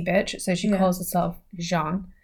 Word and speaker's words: Jeanne: However, her name is spelled J-E-A-N-N-E. So it kind of looks a bit like it should be Jean. – Oh, like Jean. – No, it's Jean.